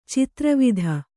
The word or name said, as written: ಚದುರುವಾತು